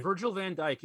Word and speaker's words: van Dijk: They have a world-class mid defender, Virgil van Dijk. I hope I pronounced that name right. – Virgil van Dijk, yeah, central Dijk, defender.